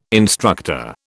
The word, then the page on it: instructor: British English